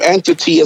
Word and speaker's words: NTT: So my name Entity is spelled N-T-T.